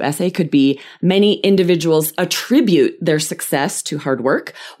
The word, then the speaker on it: attribute: What's the difference between attribute, verb, second syllable stress, and attribute, noun, first syllable stress?